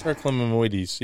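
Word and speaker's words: periclymenoides: Periclimenoides, yeah.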